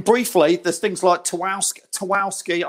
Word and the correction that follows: Tarkowski: I can never say it right, Tarkowski. – Tarkowski from Burnley for 50 million.